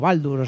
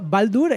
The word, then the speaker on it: Baldr: Baldur se escribe B-A-L-D-R. Yo lo traduzco como Baldur, o sea.